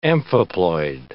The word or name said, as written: amphiploid